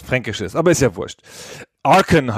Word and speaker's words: Archon: Ich neige dazu, auf der ersten Silbe zu betonen. Archon, wenn schon.